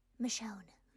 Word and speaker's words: Michonne: As someone who has watched The Walking Dead for many years, the pronunciation is... Michonne.